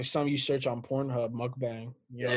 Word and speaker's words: mukbang: It's mukbang.